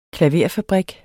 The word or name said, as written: klaverfabrik